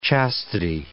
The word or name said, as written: chastity